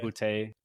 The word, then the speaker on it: Boutte: Finally took a defense in the Miami Dolphins, and then I took Boutte, or however you say his name. Wide receiver. – Boutte. – I think it's like a D, not two T's, actually.